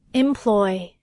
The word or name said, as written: employ